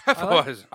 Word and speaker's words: Hefeweizen: Hefeweizen. – I like the correct pronunciation there.